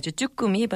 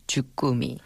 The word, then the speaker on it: 주꾸미: People usually call it as jukumi, but you know the right proper pronunciation is jukumi.